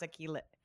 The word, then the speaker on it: Achilles: It's our superpower, but it is also our Achilles heel. I can't even pronounce Achilles.